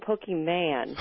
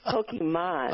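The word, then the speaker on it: Pokémon: You keep saying Pokemon. And it's Pokemon.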